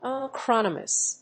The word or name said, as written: acronymous